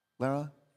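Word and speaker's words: Lérins: Is that, I'm saying that right? L-E-R-I-N-S? Leran? Yeah, Leran.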